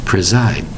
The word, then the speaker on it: preside: Preside. Notice the spelling for the word preside. P-R-E-S-I-D-E. The S in this word sounds like a Z, doesn't it? But the word preside is spelled P-R-E-S-I-D-E.